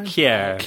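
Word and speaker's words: Kjær: Kier. – Right, okay. Well, Aga and Kier, you would expect them to have been pretty solid at the back, and they were anything but. Although Kier was fantastic going forward.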